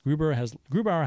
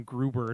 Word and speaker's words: Grubauer: Grubauer has lost 5 straight games. While it's not like you called him Gruber at first.